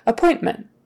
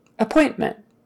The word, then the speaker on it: appointment: Stress is on that middle one. Appointment, appointment. Yes, the T is kind of soft in there, appointment, but it's okay if you make it pronounce appointment, appointment. Don't leave it out. Appointment, appointment, appointment. It's got to be in there. Appointment, appointment, appointment. Don't leave it out in the middle.